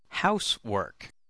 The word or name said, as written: housework